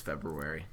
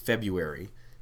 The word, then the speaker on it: February: All the other things that we're going to do this February. – Actually, every single person that listened to the podcast came up to me and said, John Michael, you're right. It's pronounced February, and it's not February.